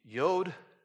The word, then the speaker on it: Yod: Tonight, it is the Yod stanza, not Yoda. That's something different. But Yod, Y-O-D, some pronounce it actually even today. Some pronounce it Y-U-D, Yud.